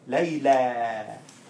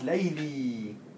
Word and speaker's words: Layla: Here, it doesn't have any dots because it's Layla. If it has two dots, it becomes Layli. Layli. And if you pronounce it Layli, then it's different than Layla. It's a different girl. Because the girl we're talking about name is Layla.